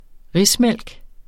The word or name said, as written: rismælk